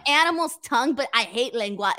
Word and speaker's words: lengua: How do I pronounce this? Lengua tacos? – Yeah, lengua.